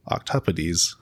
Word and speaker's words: octopodes: And finally, octopodes.